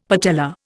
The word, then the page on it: patella: Indian